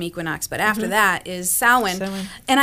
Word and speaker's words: Samhain: Samhain, yes.